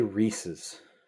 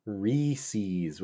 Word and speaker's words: Reese's: They don't say Reese's. – No. – They say Reese's with a long E.